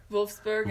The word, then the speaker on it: Wolfsburg: It's pronounced with a V sound though. Wolfsburg. – Wolfsburg.